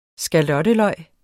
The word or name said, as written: skalotteløg